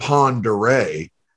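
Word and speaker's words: Pend Oreille: There's a town called Pend'Oreille. That's spelled completely different. – That's spelled like P-O-N-D-A-R A-Y, right? – Correct.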